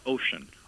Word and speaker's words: Ossian: First of all, am I pronouncing his name correctly? – They pronounce it Ocean.